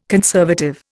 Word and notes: conservative: Indian

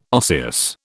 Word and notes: osseous: British English

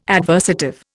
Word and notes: adversative: Indian

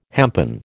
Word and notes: hempen: American English

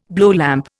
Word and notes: blowlamp: Indian

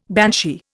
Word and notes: banshee: Indian

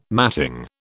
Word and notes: matting: British English

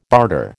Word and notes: barter: American English

